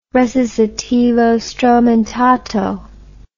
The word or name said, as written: recitativo stromentato